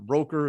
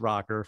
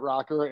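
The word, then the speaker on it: ROKKR: A lot of people are somehow don't know how to pronounce that yet, but I guess, no, like, is it Roker? Is it, no, it's not like Al on the Today Show. This is Rocker. – we pronounce it Rocker.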